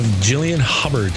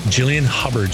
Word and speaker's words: Jillian Hubbard: We have Jillian Hubbard. Did I say that right, Jillian? Mm-hmm. I did. Perfect. Jillian Hubbard.